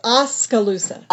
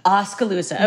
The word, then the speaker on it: Oskaloosa: Am I pronouncing that correctly? You tell me. – It is Oskaloosa. – Oskaloosa.